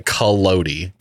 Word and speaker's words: Collodi: I would have done Collodi.